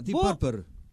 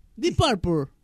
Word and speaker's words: Deep Purple: Pero Deep Purple. – Deep Purple. – Purple.